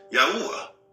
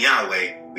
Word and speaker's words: Yahuwah: You see, when saying Yahweh, we are ignoring the Ah at the end of the pronunciation, which is a disservice to him. The Ah is the breath of the Father within his own name.